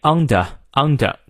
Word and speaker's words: under: 那么英式发音元音后的 r 不念啊，但这段呢元音后的 r 唯一不念的只有 under 这个词啊，英国人会念 under under，其他呢和美式发音基本相同。